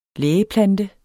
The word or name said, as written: lægeplante